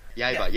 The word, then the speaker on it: Yaiba: Yaiba, yes.